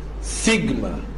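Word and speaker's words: سيجما (σίγμα): الحرف اللي بعد كده حرف سيجما. سيجما، حرف السيجما زي حرف السين او حرف الاس، تتنطق السه سيجما.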